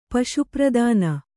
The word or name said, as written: ಪಶುಪ್ರದಾನ